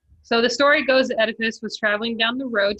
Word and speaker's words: Oedipus: Oedipus.